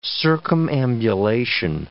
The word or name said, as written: circumambulation